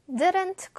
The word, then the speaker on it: didn't: 자, 근데 원어민들은 didn't 할 때 t 발음을 이렇게 정확하게 하기보다는 didn't 이렇게 묵음으로 넘어가는 경우가 꽤 있거든요.